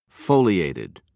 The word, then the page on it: foliated: British English